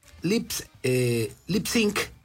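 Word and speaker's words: Lipps Inc.: Entonces, Lip Sync, como se debe de pronunciar, logró popularidades a fines de los 70s.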